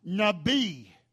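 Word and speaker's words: Nabi (נביא): It is spelled N-A-B-I-Y, Nabi, Nabi.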